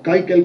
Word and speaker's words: Keuchel: Me gusta Keuchel, Dallas Keuchel, o Keuchel como se pronuncia, es el gran baluarte de ese equipo.